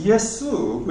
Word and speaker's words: Yesu: これあの元々がギリシャ語で書かれてた名前なんで、 and it's pronounced Yesu, which is very similar to the Japanese. – それが英語のジーザスじゃなくて日本語のイエス様のイエスにすごく似てるイエスっていうギリシャ語の発音なんです。 – Right, in English, yeah, we destroy a lot of the original pronunciation.